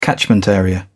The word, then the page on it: catchment area: British English